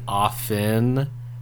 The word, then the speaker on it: often: Excuse me, often. Since we're talking about words. – Okay, I don't know. – You lost me. Yeah, I lost me too. – Because you're not supposed to pronounce the T.